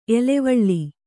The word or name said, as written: ಎಲವಲ